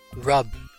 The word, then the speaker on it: rub: No, no. Rub, R U B, R U B.